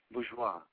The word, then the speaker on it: Bourgeois: Bourgeois. – Bourgeois. Okay, so just like in French.